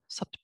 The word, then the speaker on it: supta: Also, if you pronounce it correctly, it's not like bowl of soup. It's like sup. Like sup. Sup to Baddha Konasana.